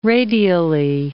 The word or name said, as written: radially